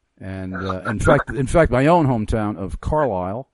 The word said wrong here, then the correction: Carlisle: I just mispronounced it right there. the locals call it Carlisle.